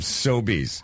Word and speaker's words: Sobeys: I just mispronounced it. I just fucked up the pronunciation of Sobeys.